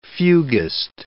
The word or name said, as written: fuguist